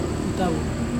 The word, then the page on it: itavung: Serewen